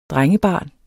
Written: drengebarn

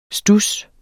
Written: studs

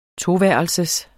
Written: toværelsers

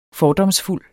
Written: fordomsfuld

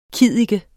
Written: kiddike

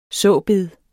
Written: såbed

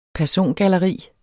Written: persongalleri

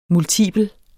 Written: multipel

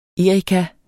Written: erika

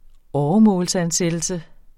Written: åremålsansat

